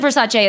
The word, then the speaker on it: Versace: He said Versace is the Italian pronunciation.